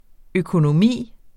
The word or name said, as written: økonomi